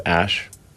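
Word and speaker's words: Æ: The AE is like... pronounced Ash.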